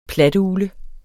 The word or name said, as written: platugle